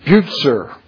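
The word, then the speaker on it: Bucer: they pronounce it Butzer. Now, that's at least the German pronunciation, but this is a Latinized name. I think that C's in Latin need to be hard C's. Is that right? Bucher? But everybody says Butzer.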